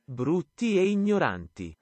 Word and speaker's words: Brutti e Ignoranti: Brutti e Ignoranti. That is the way you actually pronounce it in Italian, but translation is uh, ugly and ignorant.